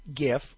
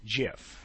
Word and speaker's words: GIF: Is it GIF or JIF? And the people who created the format would like to say that choosy developers choose JIF.